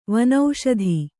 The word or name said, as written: ವನಸ್ಥ